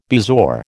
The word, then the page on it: bezoar: American English